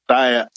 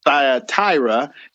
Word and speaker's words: Thyatira: I've been pronouncing it wrong all this time. See, I always pronounce it Thyatira. And I told Becky, I got to call it Thyatira. And she goes, well, you wrong. She even told me I was wrong.